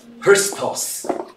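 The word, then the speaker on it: Christos (Χριστός): 它的发音是这样 Christos.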